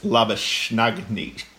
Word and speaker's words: Labuschagne: And well played to the likes of Lashenbaugh, who batted very well. – So it's pronounced Labuschagne. Cagney, it's hard, but everyone just calls him Labuschagne.